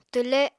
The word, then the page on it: twle: apatani